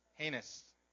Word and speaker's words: heinous: It doesn't seem so heinous. Heinous. Is that how you say it? Heinous. It's written heinous, but heinous.